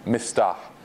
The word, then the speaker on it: Mistah: Mistah, literally spelled like Mista, okay? Mistah.